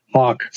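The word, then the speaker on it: Haack: I saw Hack's punt. – Yep. – Hawk. Sorry. – Yeah, no worries. – I forgot my pronunciation.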